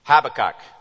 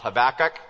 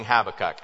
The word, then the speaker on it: Habakkuk: Emphasis on the first syllable. Not Habakkuk. A lot of people do. I'm going Habakkuk, and I have no reason whatsoever for doing it.